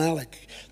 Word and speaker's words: Alex: He was an Alexander, but I'm simply an Alec. The only problem is when they wrote my name, they spell it the way the lady spelled it, A-L-E-X. The men spell it A-L-E-C.